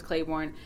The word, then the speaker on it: Claiborne: And the zingers, it's Kathy Bates plays Dolores Claiborne.